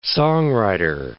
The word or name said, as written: songwriter